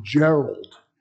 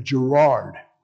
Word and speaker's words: Gerard: People always mispronounce his name. They call him Gerald instead of Gerard.